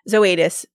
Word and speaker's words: Zoetis: but Zoetis is the, or Zoetis, people say it weird, but Zoetis is how I've heard it pronounced the most.